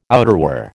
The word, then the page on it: outerwear: American English